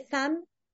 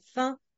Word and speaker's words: faim: Oui, le son 1, on dit j'ai femme ou j'ai fan. Non, je dois prononcer comme j'ai faim.